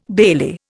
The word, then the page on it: bailey: Indian